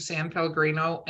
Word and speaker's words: San Pellegrino: I don't know how somebody can talk about drinking San Pellegrino in an awkward way, but you manage to do it every time.